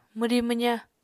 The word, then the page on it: mwri-mwza: Apatani